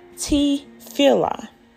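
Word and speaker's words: tefillah (תפילה): And it is pronounced tefillah. Tefillah is prayer in Hebrew.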